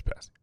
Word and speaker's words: pasty: It's pasty, right? Right, it's pasty.